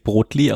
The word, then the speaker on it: Brotli: Spricht man das wirklich Brotli aus? – Ja, Brotli oder Brotli, alles, was die machen, sind ja so Schweizer Kaffee, Zöpfli und was weiß ich.